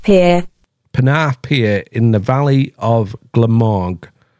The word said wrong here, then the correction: Glamorgan: Glamorgan. Fucking hell, I didn't say that right either, did I? Glamorgan. Glamorgan, Wales. Ah, fucking.